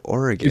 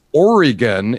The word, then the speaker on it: Oregon: Is it Oregon or Oregon? – Is it? Right. It's Oregon if you're in Ohio. It's Oregon if you're here. Oregon. Real quick on that middle syllable.